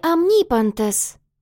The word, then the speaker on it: омнипантес: «В слове «омнипантес» ударение на «и».